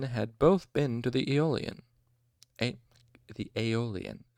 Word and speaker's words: Aeolian: The Aeolian. Okay, it's spelled E-O-L-I-A-N. Aeolian.